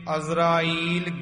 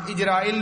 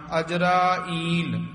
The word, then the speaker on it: अजराइल: साध संगत जी इसे तरह उच्चारण करना दुनिया मुकामे फानी इथे विश्राम लाना तहकीक दिल दानी मामसर मुए अजराइल गिरफत है अजरा बोल के ईल बोलना इजराइल नहीं कह देना कई इथे इजराइल बोल देंदे हन ओ उच्चारण नहीं करना अजराइल